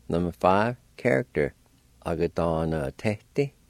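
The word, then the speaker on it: agadanvtedi: A-ga-da-na-te-ti.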